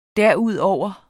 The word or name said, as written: derudover